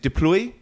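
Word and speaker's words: du Plooy: You know, I don't know how you pronounce his name. Is it Deploy? – Yeah. – Louis Deploy, yeah, near enough.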